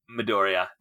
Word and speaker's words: Midoriya: Midoriya or Deku? – Midoriya.